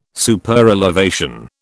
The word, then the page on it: Superelevation: British English